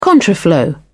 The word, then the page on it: contraflow: British English